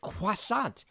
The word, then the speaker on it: croissant: And yet you pronounce it croissant, which I will admit is the correct pronunciation.